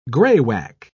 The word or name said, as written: graywacke